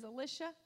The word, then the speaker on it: Elisha: Some people say Elisha. The proper pronunciation is Elisha and the Syrians.